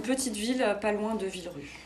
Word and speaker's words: Villerupt: Villerupt, voilà, on ne prononce ni le P ni le T, Voilà. – pourtant il y en a à la fin du mot.